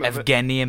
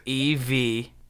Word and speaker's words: Evgeny: Look at, how do you say his first name? – Evgeny Malkin. – What? – Evgeny? Evgeny. Evgeny Malkin.